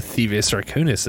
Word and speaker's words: Thievius Raccoonus: It's not the Thievius Raccoonus, is it? – There, you got it.